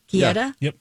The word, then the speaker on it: Guetta: Am I pronouncing musician David Guetta? – Guetta. – Okay.